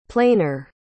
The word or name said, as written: plainer